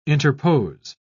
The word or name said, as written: interpose